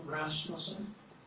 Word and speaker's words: Rasmussen: My name is John Rasmussen, 6650.